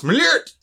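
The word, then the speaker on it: Malört: It's pronounced Malort!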